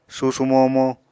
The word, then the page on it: susumomo: apatani